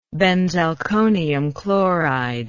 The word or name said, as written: benzalkonium chloride